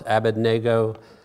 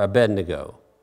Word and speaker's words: Abednego: And they changed his name to it can either be pronounced Abednego or Abednego.